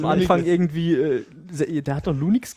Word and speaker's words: Linux: Pronounce Linux as Linux.